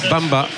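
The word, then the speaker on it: Mbamba: On ne prononce pas le M hein, chez les joueurs africains. Bamba. Enfin, le M du début du nom, je dirais. Donc la sortie de Congrève, comme on dit par exemple, Bappé.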